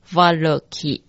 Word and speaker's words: Valoki: 第三个字是像英文 KI 这样的音 KI， VALOKI。